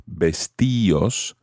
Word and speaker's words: vestíos: The D comes off and you have vestíos. However, you have to add an accent to the I in order to keep that type of pronunciation.